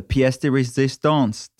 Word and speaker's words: pièce de résistance: Now we're in week four, the pièce de résistance. Did I say that right? – Yes, you said that perfectly.